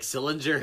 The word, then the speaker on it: Sillinger: Rhymes with... oh, man. How can I not give it away? Billinger. – Mike Sillinger.